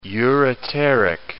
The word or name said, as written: ureteric